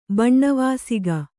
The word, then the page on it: ಬಣ್ಣವಾಸಿಗ: kannada